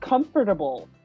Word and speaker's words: comfortable: I was going to say, it reminds me of the word comfortable. I've never said comfortable. I always say comfortable. Because it makes me comfortable to say comfortable. It's really comfortable to take out that fourth syllable.